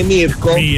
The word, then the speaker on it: Mirko: Non si dice a me mi piace, ma a me mi piace, perché a Roma si dice il mio nome è Mirko.